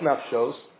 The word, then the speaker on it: Nafshos: It says it's pronounced Nafshos, but it's written Nafshas.